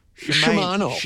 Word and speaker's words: Shimano: Shimano.